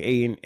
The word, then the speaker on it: Ayton: Aiden. – Yeah, DeAndre Ayton.